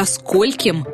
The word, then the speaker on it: скольким: Правильно спрашивать «ко скольким мне подойти в офис?». При этом ударение будет всегда падать на первый слог.